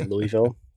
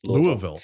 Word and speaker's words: Louisville: I am in Louisville, Kentucky. That's how you pronounce that, right? – You. – A lot of people say Louisville. It's not the way. It's Louisville.